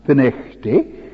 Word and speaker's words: Findochty: We have heard that during the 1859-60 revival, conviction of sin was so strong in the area of the fishing village of Findochty, which is locally pronounced Finechty,